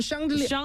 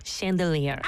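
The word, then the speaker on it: chandelier: 근데 불어로는 샹들리에. – 샹들리에. – 샹들리에. – 샹들리에. – 네. – 네. 영어로 어떻게 하죠? – 영어 뭐 미국 발음으로는 샹들리에. – 샹들리에.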